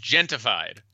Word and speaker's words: Gentified: We've got Gentrified, which is a Netflix comedy. – Quickly, it is Gentified. – Gentified. There's no R? – There's no R. It is spelled wrong on purpose.